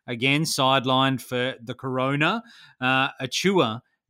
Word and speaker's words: Achiuwa: Achiuwa. – Bless you. Precious Achiuwa